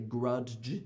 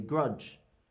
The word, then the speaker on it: grudge: But the letter D is not pronounced in the DG combination. We don't say grudge, we say grudge. And it just kind of makes a J sound.